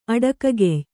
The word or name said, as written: ಅಡಕಗೆಯ್